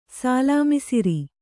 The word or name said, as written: ಸಾಲಾಮಿಸಿರಿ